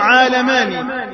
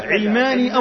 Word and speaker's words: عالماني: ولهذا النطق الصحيح له عالماني، لأنه ينسب إلى العالم المفتوح اللاديني، ولكن هم بدأوا بنطقه هكذا علماني أو علماني.